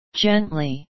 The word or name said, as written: gently